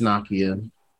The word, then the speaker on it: Nakia: Is it Nazia? Have I been saying her name wrong the whole time? It's Nakia. – Oh, it's definitely Nakia.